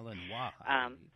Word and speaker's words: Malinois: And in case anyone doesn't know, Nero is a 2.5-year-old Belgian Malinois. I think that's how you say it. – Malinois, yeah.